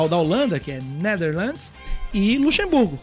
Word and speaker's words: Netherlands: Netherlands. Netherlands, muito bem, muito obrigado.